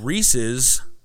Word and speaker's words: Reese's: They are called Reese's peanut butter cups. Alternately, the candy-coated bite-sized peanut butter bites are Reese's Pieces. They were named thusly because Reese's rhymes with pieces. They are not Reese's.